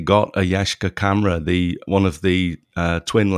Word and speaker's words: Yashica: Do you pronounce it Yashica or Yashica? – It's Yashica.